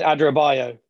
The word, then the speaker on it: Adarabioyo: That West Ham were looking at signing Tosin Adarabioyo, or how he pronounced his surname, Adarabioyo. – Tosin Adarabioyo.